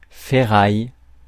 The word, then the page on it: ferraille: France; [fɛ.ʁaj]; French